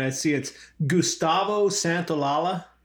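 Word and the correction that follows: Santaolalla: Santolala. – I have a A after the L's. – Santa Olala. – Santa Olala. All right.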